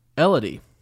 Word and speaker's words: Elodie: First raven is from Elodie, which rhymes with melody.